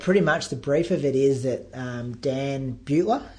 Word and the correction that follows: Buettner: how do you pronounce his name? – I think it's Buettner, isn't it? – Buettner. – Yeah, Buettner, that's it, yep.